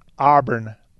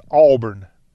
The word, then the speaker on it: Auburn: It's not Auburn, Auburn, with like an R in there. It's Auburn. A-W-E-B-U-R-N.